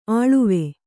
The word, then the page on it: ಅಳ್ವು: Kannada